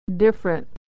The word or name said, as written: different